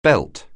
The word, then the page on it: belt: anglais